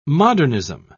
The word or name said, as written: modernism